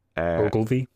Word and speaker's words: Ogilvie: Ogilvy. – Ogilvy, yeah. I was thinking about how to pronounce that, but yeah, Ogilvy sounds good to me.